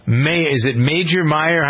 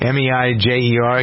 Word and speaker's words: Meijer: Major Meyer? How do you pronounce that? I don't know that store chain. – Don't know it. – Meijer.